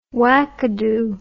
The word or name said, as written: wackadoo